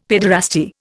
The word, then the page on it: paederasty: Indian